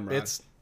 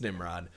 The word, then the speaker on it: Nimrod: It's nope, Nimrod. It's Nimrod.